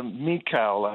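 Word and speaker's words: Michal: Well, I think that Mikal, I think, is the way that we pronounce her name.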